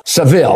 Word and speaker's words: Sevilla: The barber of Sevilla shaves everybody in Sevilla who doesn't, I hope I'm pronouncing that right, Sevilla, Seville, I'll pronounce it like an American, the barber of Seville, okay?